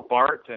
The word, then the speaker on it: Barth: Karl Barth, and I emphasize that's a hard T, even though his surname is spelled with a T-H.